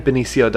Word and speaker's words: Benicio: And apparently it's Benicio. – Benicio? – Yeah. – I didn't know. – Well, at least that's how I heard somebody who is smarter than us pronounce it, so.